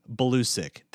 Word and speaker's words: Balusek: And again, our guests today have been Darryl Herrington and Mike Balusek. Did I say it right? – Balusek. You got it.